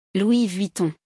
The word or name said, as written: Louis Vuitton